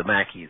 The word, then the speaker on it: Maquis: Could you tell us a little bit about both the Freedom Trail and then also the French Maquis? Is that how it's said, the guerrilla resistance group in France that your dad later joined? – Yes, the Maquis.